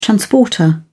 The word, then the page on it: transporter: British English